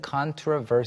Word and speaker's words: controversial: 발음이 이제 controversial이라고 발음하는 경우가 저는 가장 익숙하고 많이 mm-hmm. 들었는데 어떤 사람들은 controversial seal. – 이렇게 발음하는 경우도 있어요.